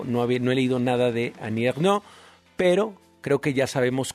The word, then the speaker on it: Annie Ernaux: Annie Ernaux.